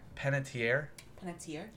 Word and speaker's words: Panettiere: Panettiere? Panettiere. That's how I would say it.